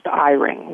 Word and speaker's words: Eyring: And you can help them by, like, my last name is pronounced Eyring, like an I and a ring.